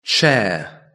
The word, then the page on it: chair: British English